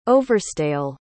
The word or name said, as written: overstayal